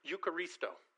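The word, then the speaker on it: eucharisto (εὐχαριστῶ): The word thanksgiving in the Bible is eucharisto. And if we break it down, there's elements of that word that you're already familiar with. The E-U is pronounced you.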